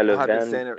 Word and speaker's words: Le Vainqueur: Is that how you say it? Is that okay? We're gonna hit it ourselves, but is that the proper way to say that? Le Vainqueur? – Yeah, Le Vainqueur. That's how I've been saying it.